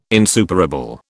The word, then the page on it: insuperable: British English